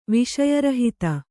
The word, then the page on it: ವಿಷಯರಹಿತ: viṣaya rahita